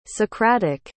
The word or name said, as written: socratic